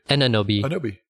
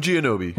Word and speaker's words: Anunoby: New York Knicks, OG Anunoby. Did I say that? – OG Anunoby. OG Anunoby. – When I read it, it doesn't look like I say it right.